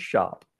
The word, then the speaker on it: Schaupp: S-C-H-A-U-P-P, spelled just like it sounds, shop.